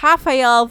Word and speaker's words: Rafael: but because Rafael dos Anjos is Brazilian, you're supposed to pronounce the R as a H sound. So it's Rafael versus Rafael Fiziev.